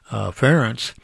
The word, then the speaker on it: Ferenc: Well, I thought, yesterday Nathan and I learned how to pronounce the Hungarian name, Ferenc, as Ferenc.